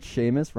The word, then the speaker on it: Sheamus: Do you know that Sheamus rhymes with anus?